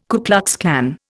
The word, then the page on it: Ku Klux Klan: Indian